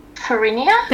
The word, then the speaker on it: Farina: How do I say it? F-A-R-I-N-A. – Farinia? – Thank you.